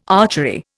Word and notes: archery: Indian

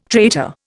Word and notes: traitor: Indian

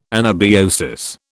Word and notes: anabiosis: British English

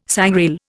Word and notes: sangrail: Indian

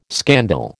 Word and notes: scandal: American English

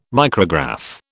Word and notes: micrograph: British English

